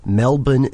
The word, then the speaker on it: Melbourne: Melbourne and Brisbane.